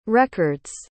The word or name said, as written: record's